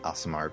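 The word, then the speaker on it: Aasimar: It's pronounced Asimar, but yes.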